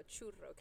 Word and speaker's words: churro: It sounds like the R turns into an L. – No, churro. Can you roll your R's?